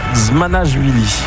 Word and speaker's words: Dzmanashvili: Alors, on ne va pas vous en vouloir parce qu'effectivement, Georgi, tout va bien, mais derrière, ça s'écrit DZ. – C'est pas courant. – DZMANASHVILI, consonne, voyelle, consonne. En géorgien, ça donne Zmanashvili.